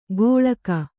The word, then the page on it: ಕೊಳ್ಕ: koḷka